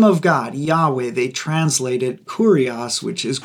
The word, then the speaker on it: Yahweh: When scholars finally, much, much later, figure out what was going on, uh, they guess that the name was probably pronounced Yahweh. I'm not sure exactly how they came to that conclusion, or Yahweh.